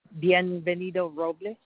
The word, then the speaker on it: Bienvenido Robles: Bienvenido Robles. – Can you spell that for me? – It's B for boy, I-E-N.